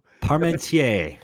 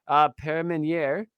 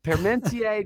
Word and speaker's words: Parmentier: His surprise at the outcome led Parmentier. Dang, I said it wrong again. Parmentier to become a pioneering.